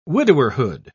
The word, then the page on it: widowerhood: British English